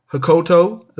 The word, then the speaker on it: Hokuto: We get Akira Hokuto.